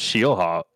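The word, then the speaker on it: Scheelhaase: It's just I think it's just Shielhaas. – Shielhaas. – Like Shields. Shields. The boarding goods store, and then Haas. – The store. – Like Haas Racing.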